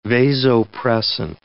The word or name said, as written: vasopressin